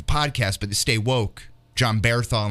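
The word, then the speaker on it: Jon Bernthal: I love John Bernthal. Is that, am I pronouncing that right? – Yeah, you got it right.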